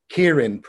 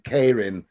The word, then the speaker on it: Keirin: that you've got the Keirin pronunciation for the international version and Kairin as the pronunciation for the original Japanese discipline